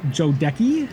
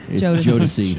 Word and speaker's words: Jodeci: Uh, is this pronounced Jodecki? – Jodeci.